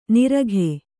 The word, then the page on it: ನಿರಗೆ: Kannada